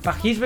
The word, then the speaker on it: Paris: Lo hizo, y voy a tratar de decirlo lo más parecido a la pronunciación francesa, lo hizo en el Le Défilé de Royal Paris 2026. ¿Qué tal tuvo la pronunciación, muñeca? Pajis, bueno, Pajis, ah, es como con G. Ah, no es Pajis, claro, me suena medio raro. Pajis, ahí está.